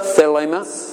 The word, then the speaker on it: Thelema: Thelema. T-H-E-L-E-M-A. T-H-E-L-E-M-A. T-H-E-L-E-M-A. So come in. – Is it like Thelma? – Huh? – Is it almost like Thelma? – Thelma?